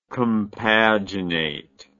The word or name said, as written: compaginate